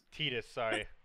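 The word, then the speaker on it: Tidus: Yes, that is Clouds and Titus's from Final Fantasy 10. – It's pronounced Titus. – Titus, sorry.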